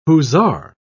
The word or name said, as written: hussar